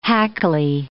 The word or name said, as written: hackly